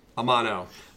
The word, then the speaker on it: Amano: Amano.